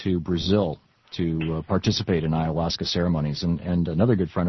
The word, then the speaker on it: ayahuasca: 8-0, they said that the government cannot hinder the use of peyote or of a tea called Hosca that has hallucinogenic. – It's pronounced ayahuasca, actually. – Oh, how do you say it? – Ayahuasca. – Ayahuasca, okay.